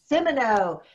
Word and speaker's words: Simoneau: Simoneau.